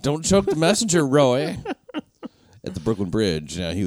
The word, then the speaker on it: Roee: Roy. He spells his name Roy. R-O-E-E. – R-O-E-E.